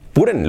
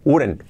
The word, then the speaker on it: wouldn't: I wouldn't, wouldn't, lo he dicho malo, wouldn't, wouldn't.